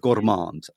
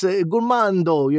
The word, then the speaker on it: gourmand: There's no D. You don't pronounce the D. Yeah. – If you put a D, it's gourmando. You're always trying to make it closer to Italian. It's just gourmand.